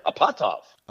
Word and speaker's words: Apatoff: the name is actually Russian and Ukrainian. It's pronounced Apatoff.